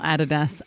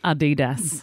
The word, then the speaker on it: Adidas: Get back in your snap pants and just then make sure you're calling them Adidas. – Adidas. – Oh, stop it. – Yeah, Adidas. – Oh, that's terrible. – Yeah, that's the way it is. Not Adidas, Adidas.